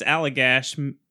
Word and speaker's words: Allagash: Okay, so this starts on August 20th, 1976, in I think it's Allagash is how you pronounce it.